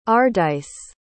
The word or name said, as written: ardyce